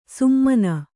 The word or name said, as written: ಸುಮ್ಮಾನ